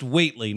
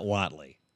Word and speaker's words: Whateley: So it's pronounced Whateley, not Whatley. Although it's spelled like Whatley, like Joe.